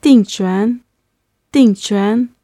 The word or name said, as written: dingchuan